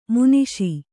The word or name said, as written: ಮಾನುಷ